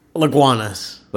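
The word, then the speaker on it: Lagunitas: And according to one of our listeners, you said the name wrong. – Yes. – What's the new name? Say it now so you get it out of the way. – Laguanas. – Laguanas. – Yeah.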